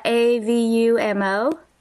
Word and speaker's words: Avumo: Avumo is spelled A V U M O.